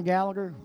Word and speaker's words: Gallagher: Just search John Gallagher. And you spell that last name for us? – Yeah, sure. John, J-O-H-N, Gallagher, G-A-L-L-A-G-H-E-R.